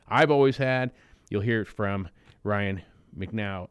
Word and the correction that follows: McEwen: Or, I'm sorry, I always say his name wrong. McEwen.